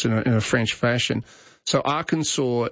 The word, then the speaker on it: Arkansas: But I think that's the difference, that Arkansas has adopted the French pronunciation in the same way that New Orleans has, as opposed to New Orleans, as we tend to say it. But Kansas is the English pronunciation.